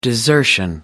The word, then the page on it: desertion: American English